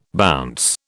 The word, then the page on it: bounce: British English